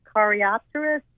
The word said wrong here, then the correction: Coreopsis: Am I saying that correctly? – Yeah. – Coreopsis, yeah.